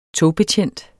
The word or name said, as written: togbetjent